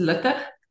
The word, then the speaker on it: Luttig: I'll give you the Afrikaans, which most people struggle with. It's Luttig.